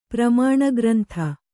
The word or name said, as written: ಪ್ರಮಾಣಗ್ರಂಥ